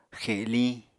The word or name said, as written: xeliñ